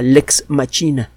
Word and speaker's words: Lex Machina: Hay otra que se llama Lex Machina, o lex machina sería la pronunciación del latín.